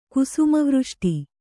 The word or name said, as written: ಕುಸುಮವೃಷ್ಟಿ